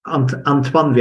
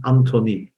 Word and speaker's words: Antoine: Also die Integumentschäden müsstest du noch richtig aussprechen und mein Vorname, das ist ja Antoine wird der ausgesprochen. Hier in Deutschland sagen gerne Anthony, ja. Aber das ist Antoine, kommt aus dem Französischen.